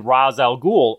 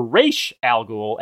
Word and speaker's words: Ra's al Ghul: According to one of the character's original creators, Denny O'Neill, Ra's is how Ra's is pronounced in Arabic, but O'Neill said he once spoke to a professor who claimed it should be Ra's. So both pronunciations are used frequently in adaptations. It's Ra's in Batman Begins, but Ra's in the animated series.